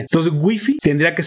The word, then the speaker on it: wifi: Escribe con W, I, F I y entonces la RAE dice, bueno, entonces nada más pronúncialo en su idioma original. Wi-Fi, Wi-Fi, de hecho, Wi-Fi. Y eso aplica con cualquier palabra del ruso, del, chino, del japonés, del suizo.